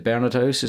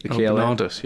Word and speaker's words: Bernardus: And speaking of which, is it Bernard House? Is it the Dutch Open this week? – Bernardus.